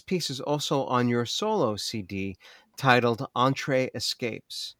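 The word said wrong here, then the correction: Entre Espace: It's Entree Espace, Between Spaces.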